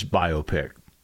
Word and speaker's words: biopic: Biopic, whatever. So it should rhyme with myopic.